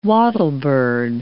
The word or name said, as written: wattlebird